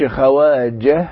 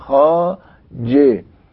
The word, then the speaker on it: خواجه: ووفقا للقاعدة السابقة احنا هنلاقي ان الهاء هتبقى صامتة لان اللي قبلها مكسور، ما اقولش خواجه انما بقول خاج. الواو جت بين الخاء والالف، برضو الواو لن تنطق، عشان كده بنقول خاج.